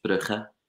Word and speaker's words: Brugge: Now, I was eventually, I get sent to Bruges By the way that we pronounce that in Flemish or in Dutch is Brugge, believe it or not. And I can't believe I just said Bruges.